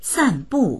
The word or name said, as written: san4bu4